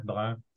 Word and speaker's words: Brun: It's always hard for an Anglophone to pronounce a French name. So my full name is Jean-Jacques Joseph Brun, but I go by JJ.